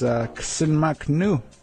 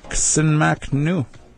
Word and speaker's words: ksenmaknu: And how you say that in New Hulk is ksenmaknu. Ksenmaknu. You are working. You work. Ksenmaknu.